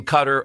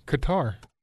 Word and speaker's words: Qatar: They told the people in Qatar, and then Qatar alerted the U.S. military. I don't know about how specific it was. You had something else? – Qatar. – Oh, Qatar. That's right.